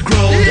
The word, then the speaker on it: Grohl: Rhymes with Bob Dole. – Yeah! – It's time to play Rhymes with Grohl.